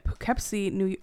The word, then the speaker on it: Poughkeepsie: A murder victim, a contractor working on a vacant home in Poughkeepsie.